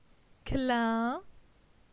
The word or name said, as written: Clin